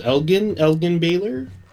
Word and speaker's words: Elgin Baylor: And then next up we have I believe this is pronounced Elgin Baylor.